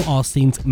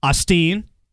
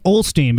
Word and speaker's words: Osteen: I've been saying it Olsteen, but I don't think that's correct.